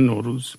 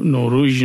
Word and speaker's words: نوروز: میگن نوروز، یعنی نوروژ نمیگن، یعنی از فارسی رفته به تمام این زبانهای مختلف، از قرقیزی بگیریم تا قزاقی تا ترکی آذربایجانی قفقاز.